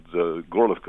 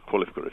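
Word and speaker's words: Horlivka: And so large parts of Ukraine, which were previously at peace, which I'd visited a few years before, I'm thinking of a town called Gorlovka, or Kholivka, if you prefer the Ukrainian pronunciation, are now death zones, to the total surprise of their inhabitants.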